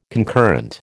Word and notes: concurrent: American English

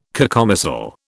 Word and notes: cacomistle: British English

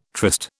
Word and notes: tryst: British English

